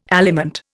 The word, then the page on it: aliment: Indian